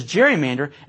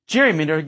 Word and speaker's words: gerrymander: Gerrymander gives us word nerds a twofer. And notice how the hard G in Gerry's name changed to a soft G in gerrymander as the term acquired its own meaning, independent of an association with the Massachusetts governor.